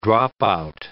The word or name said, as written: dropout